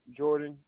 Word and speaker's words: Jordan: It's Aaron, A-A-R-O-N, and last name Jordan, J-O-R-D-A-N.